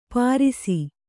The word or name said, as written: ಪಾರಿಸಿ